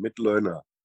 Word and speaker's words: Mitloehner: It's like to learn, Mitloehner.